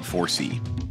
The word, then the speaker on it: FORESEE: The name 4C is a bit of a play on words. It's spelled F-O-R-E-S-E-E, but it also stands for the four C's of energy efficiency: cost, comfort, convenience, and carbon.